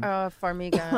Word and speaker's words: Farmiga: Farmiga.